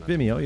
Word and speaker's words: Vimeo: How do you say it? Vimeo? – Yeah. – Vimeo? I don't Vimeo, yeah.